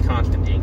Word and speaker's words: Constantine: And I'm like, that's not how you pronounce it. I don't give a fuck what Alan Moore says. Alan Moore's a great writer, and Alan Moore's done some fantastic work for DC over the years. That said, I don't agree that it's Constantine. I think it's Constantine, because that's how we were always taught, you know, Emperor Constantine of Rome, who founded Constantinople. Like, it wasn't Roman Emperor Constantine, like, and so he pronounced it, you know, he pronounced it Constantine,